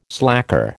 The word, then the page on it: slacker: American English